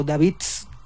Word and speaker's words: Davids: Como lo define su fundador, que es Andreas Davids, o Davids, si lo pronunciamos correctamente, el Shotox, su sonido es algo así como música industrial para gente hiperactiva, industrial for hyperactive people.